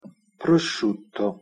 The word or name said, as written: prosciutto